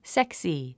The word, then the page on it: sexy: séksi; セクスィ